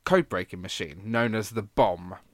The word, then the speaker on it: Bombe: Now, it's spelt B-O-M-B-E. For the rest the of the bomb. War... Sorry, go on. – Sorry, I just said that in a slightly French accent because I had an E on the end.